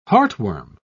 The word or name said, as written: heartworm